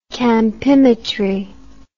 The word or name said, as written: campimetry